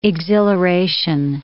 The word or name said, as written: exhilaration